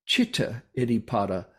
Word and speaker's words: Citta Iddhipada: Citta Iddhipada. The C is pronounced like a CH in Pali.